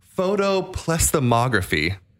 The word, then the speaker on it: photoplethysmography: Well, this uses a science called, I'm going to try to pronounce this properly, photoplethysmography is the correct way to pronounce it.